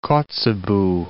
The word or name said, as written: Kotzebue